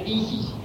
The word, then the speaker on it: Isis: que se pronuncia Isis, en dos, se reparte en dos sílabas. Y que el la S, es como un silbo dulce y apacible.